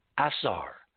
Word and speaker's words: Asar: And Orion, of course, in the Egyptian, if it's anglicized, is pronounced Asar. A-S-A-R.